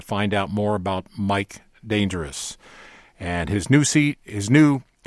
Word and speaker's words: Dangeroux: I needed a pronunciation guide, so I sent an email to a Chicago-based blues promoter, and I said, how do I pronounce D-A-N-G-E-R-O-U-X? It's like danger, and at the end, the last three letters are O-U-X. And he said, oh, I know how to pronounce it. Mike pronounces it dangerous.